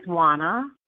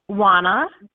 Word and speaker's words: Juana: My name is Juana. – I'm sorry, what's your name? – Juana. – How do you spell that? Is you J-U-A-N-A? – Yes.